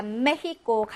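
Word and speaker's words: México: 자, 그러면 다시 이제 문항으로 돌아와서 자, 메히코 강한 히읗 발음이었습니다. 발음 같은 거 찾아보도록 하겠습니다.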